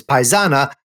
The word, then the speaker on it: paisana: So glad to have a fellow paisana. If I said that right, you sure did. – Paisan, but when you say female, it's with the A, so it's paisana, a fellow Italian from Sicily.